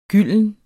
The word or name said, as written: gylden